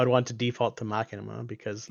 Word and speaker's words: Machinima: Machinima. Machinima. Mm. That is Machinima. Yep. My God, the machine. – Mm-hmm. Um, not Machina, as in Deus Ex Machina, but but, but Machinima because it's being made in a machine.